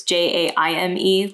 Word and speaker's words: Jaime: Uh, my name is spelled a little funny, so it's J A I M E, like the Spanish way you say Jaime.